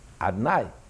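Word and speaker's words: Adonai: We never pronounce it as written. How do we pronounce it? Adnai. And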